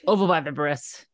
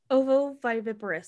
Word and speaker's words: ovoviviparous: Ovoviviparous. – Ovoviviparous.